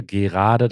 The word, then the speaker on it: gerade: Und er hat zum Beispiel ähm, mal nach bestimmten Wörtern gefragt, bei denen der Vokal im gesprochenen Deutsch weggelassen wird. Gerade und gerade, gerade, da spricht man ja das E nicht mit aus, gerade.